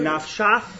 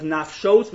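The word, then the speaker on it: nafshos (נפשת): That pasuk over there in Parashas, this week's Parasha, in Parashas Emor, according to Rabbi Akiva, the word nafshos is spelled without a vav, so really it could be nafshach, which is a machlokas there about yesh eim l'mikra yesh eim l'mesora. Rabbi Akiva holds, we pronounce it as nafshos,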